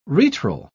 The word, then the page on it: retral: British English